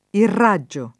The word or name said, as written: irraggiare